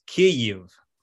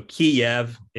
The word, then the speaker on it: Kyiv: The correct pronunciation, the Ukrainian pronunciation of the city is Kyiv. So Kyiv is the Russian pronunciation,